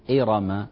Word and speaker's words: إرم: بالنسبة إلى كلمة إرم، إرم، لاحظوا الراء هنا مفتوحة، فلا نقول إرى إرى، لكن قد يكون بعض اللهجات يغلب عليها يعني صعوبة نطق في الراء وتغلب جانب ترقيق، فنقول إرى إرم إرم.